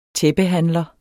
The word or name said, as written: tæppehandler